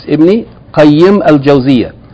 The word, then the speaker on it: Ibn Qayyim Al-Jawziyyah: In the second place, it's not mentioned Al-Qayyim, it's Ibn Qayyim Al-Jawziyyah. And that's because these are, in Arabic language, words of idafa, and there are rules of idafa that require the name to be pronounced like this.